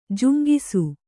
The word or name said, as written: ಜುಂಗಿಸು